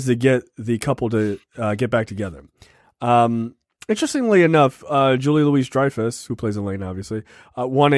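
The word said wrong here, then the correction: Louis-Dreyfus: Please stop pronouncing the S in her name. And yes, she, it is well deserved. – Oh, is it Julie Louis Dreyfus? – Yes. Oh, not sorry. Julia Louis Dreyfus.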